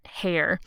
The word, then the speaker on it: Heyer: So this book is called Venetia by an author, Georgette Hare, and Hare is spelled H-E-Y-E-R.